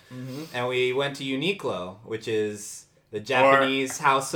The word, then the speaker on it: Uniqlo: Apparently on the PA system, it's pronounced Uniqlo. – Uniqlo.